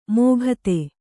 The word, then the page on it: ಮೋಘತೆ: Kannada